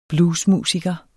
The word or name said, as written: BLUES